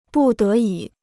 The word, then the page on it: 不得已: bù dé yǐ